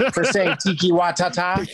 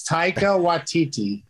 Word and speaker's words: Taika Waititi: For saying Tiki Watata. – That's not how it's pronounced, is it? – No, it's Taika Waititi.